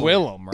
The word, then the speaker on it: Willem: William or Willem? – Willem Dafoe. Because it's Willem, right? – It is spelled Willem.